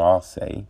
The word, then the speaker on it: Arce: Now Arosa, he's lost his last three. Okay. So his last fight was against Julio Arosa, Julio Arce, yeah, that's how you say it.